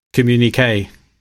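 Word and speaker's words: Communiqué: So our top album is now Communique, which I pronounced right.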